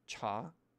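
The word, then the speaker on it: cha (茶): If you want to pronounce it right, it's a rising tone. It's not cha. It's not cha. It's not cha. It's cha Chaguan, tea shop.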